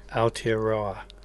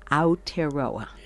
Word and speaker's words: Aotearoa: Now, please pronounce for me that Uku... – Uku Aotearoa. – Aotearoa. – Yes.